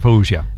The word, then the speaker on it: Fawzia: Dit is Fawzia. – Fawzia. – En Fawzia, ja, ik geloof het graag. – Zo moet je dat uitspreken, Fawzia.